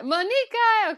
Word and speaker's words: Muniqui: No, Muniqui.